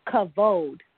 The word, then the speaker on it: kavod: And if you think about it, it's spelled with vowels, K-A-V-O-D. But when you say it, when I thought of it, it's very similar, or it rhymes with commode, like the toilet.